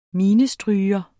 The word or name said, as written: minestryger